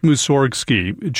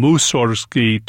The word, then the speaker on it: Mussorgsky: He added one letter, so the pronunciation of his last name would change from Mussorgsky to Mussorgsky. That's because in Russian, the word Mussor means garbage. He thought he'd be taken more seriously if his name didn't have that connotation.